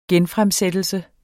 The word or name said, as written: genfremsættelse